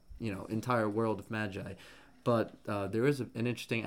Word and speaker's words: Magi: I think a better character to compare him to is Sinbad from Magi, or Maggie, because they say Maggie in the show, but I pronounce it Magi.